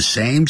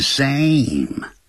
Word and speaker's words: same: In addition，此外，这是固定的短语， we both，咱们俩叫 we both， go to the same school and study in the same class，念同样的学校。 the same， same 这个字前面一定要加的，不要念成 Sam，要念 same。